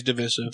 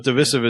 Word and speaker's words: divisive: I usually use divisive. – Divisive is the accepted pronunciation, and I just can't get it out. – Otherwise, it sounds like a device.